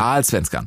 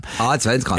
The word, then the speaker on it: Allsvenskan: Hab ich's richtig gesagt? Allsvenskan. – Allsvenskan. – Allsvenskan. – Ja, die Allsvenskan.